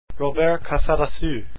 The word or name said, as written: casadesus